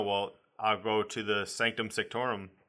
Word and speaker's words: Sanctum Sanctorum: I said that wrong, I think. – You said it weird, but you said it right. – Okay. – you hit all the letters, but you said like you were unsure of yourself, so you're like, Sanctum Sanctorum.